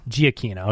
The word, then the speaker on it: Giacchino: How do you say it's Giacchino? – Giacchino.